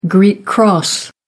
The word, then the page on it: Greek cross: American English